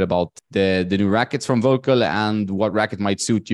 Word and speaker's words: Völkl: I have an interesting guest today, Nick Mitchell, who's the promotions manager for Völkl. That's the way you pronounce it in German, I would guess, but Völkl, we say here.